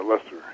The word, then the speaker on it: Lester: The, the uh, title poem, "The Ridge," is written in memory of uh, Judy Leister. Is that, is that how you pronounce her name? Uh, Lester. Lester, pardon me, and and Barry Lopez.